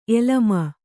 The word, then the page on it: ಎಲಮ: elama